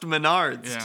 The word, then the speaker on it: Menards: And it's pronounced Menards, too.